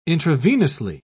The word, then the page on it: intravenously: British English